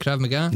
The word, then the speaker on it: Krav Maga: Krav Maga? – Nailed it.